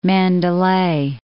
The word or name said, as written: Mandalay